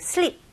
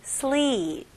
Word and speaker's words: sleep: 뭐 sleep 이것도 똑같아요. sleep 이게 아니고 sleep.